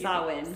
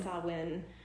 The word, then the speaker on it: Samhain: Samhain. – Samhain, which is like a very thinned veil time to the spirit world.